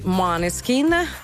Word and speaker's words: Måneskin: Dicevamo dei concerti, ieri sera Circo Massimo, Roma, 70.000 persone per i Måneskin, che mi fa caldo.